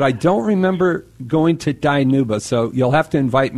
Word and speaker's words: Dinuba: It's actually pronounced Dinuba. – Dinuba? – It's okay. We're a really small town, just about 30 minutes outside of Fresno in the Central Valley. – Okay. Dinuba. Yeah. I apologize for not pronouncing it correctly.